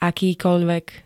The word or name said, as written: akýkoľvek